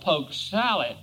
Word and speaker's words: poke salad: He went on to say, poke salad, also called poke salad, which is the right way to say it, is an absolute and truly southern plant.